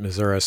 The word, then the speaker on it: Missouri: My late father was from Kansas City and pronounced it Missouri, so please don't send me correction emails.